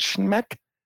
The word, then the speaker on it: Schmeck: How do you say, is it Schmeck? – It's S-C-H-M-E-C-K, so I'm going to go with Schmeck. – Schmeck. Tough name to go through life with. – It is. – Jared Schmeck is an electrician,